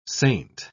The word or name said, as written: saint